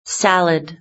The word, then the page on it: salad: British English